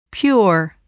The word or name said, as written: pure